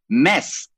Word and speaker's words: Mads: Dieser dänische Name, also M-A-D-S geschrieben, spricht sich aber tatsächlich Mess aus. Also der Mann heißt Mess. Ja, Mads Mikkelsen, das ist wie bei Django Unchained, the D is silent. Also im Dänischen wird das D ganz oft nicht mitgesprochen. Und Mads hingegen ist ein Name, der schreibt sich M-A-T-S, wie zum Beispiel Mads Wielander.